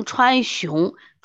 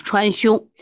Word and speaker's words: 芎: 这个药本身也只有这个七味药。这个方歌是柴胡疏肝，芍药凶，这个是川芎，这个字念凶字啊。柴胡是个药，它能疏肝；芍药是个是个药，我们养阴的啊。另外呢，它也能治疗这个腹痛，因为它可以缓急治腹痛。芎是川芎，我们老读成川芎，准确的读音是川芎。